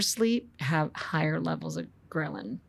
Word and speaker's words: ghrelin: And ghrelin sounds like a gremlin, and it's spelled G-H-R-E-L-I-N.